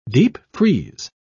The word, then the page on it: deepfreeze: British English